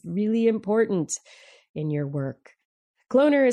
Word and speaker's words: CloneR: Cloner, rhymes with donor.